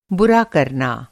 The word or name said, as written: कर